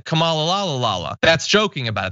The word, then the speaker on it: Kamala: said her name is pronounced comma, like the punctuation mark, la, Kamala.